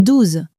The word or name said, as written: douze